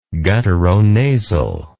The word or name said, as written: gutturonasal